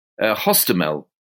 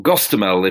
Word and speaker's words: Hostomel: And it was a helicopter assault which the Russians chose to use to try and take this key airport northwest of Kyiv, Hostomel, or Gostomel, if you want the more Russian pronunciation.